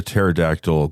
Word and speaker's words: pterodactyl: Why can't you hear a pterodactyl going to the bathroom? – Because it's extinct? – No, because the P is silent.